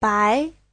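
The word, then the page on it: 白: bai2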